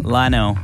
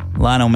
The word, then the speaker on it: Lionel: Lionel. – Okay. – Lionel Messi. – Right. Are you sure? – L-I-O-N-E-L.